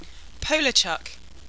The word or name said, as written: Polarchuck